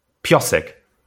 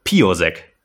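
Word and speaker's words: Piossek: Und zu erkennen mit einem Doppel-S, dadurch wird das O verkürzt ausgesprochen. Piosek. Wie man ihn nicht ausspricht, Piosek.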